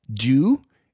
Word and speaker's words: Due: But an app that I've been using that's really helping me be more focused is an app with a terrible icon called Do. It's spelled D-U-E.